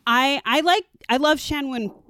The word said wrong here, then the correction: Park Chan-wook: I can never say his name. – Park Chan-wook.